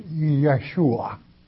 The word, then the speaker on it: Yeshua: There are no J's in the Hebrew tongue, so Jesus in that tongue has to be pronounced Yeshua, which it means Yahweh's Savior.